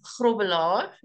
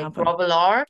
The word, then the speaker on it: Grobbelaar: Okay, so I'm Bonita, and my surname is Grobbelaar, if you're going to pronounce it correctly, but most people say Grobbelaar in the US.